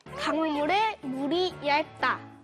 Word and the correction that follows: 얕다: 발음을 정확해 줘야 되는데요. 얇은 건 가는 걸 이야기해요. 우리가 지금 이야기하고 있는 깊다의 반대말은 얕다. 자, 선생님을 따라해 봅니다. 얕다.